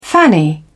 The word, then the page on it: fanny: British English